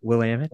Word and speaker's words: Willamette: Willamette. Is that how you pronounce it? Willamette.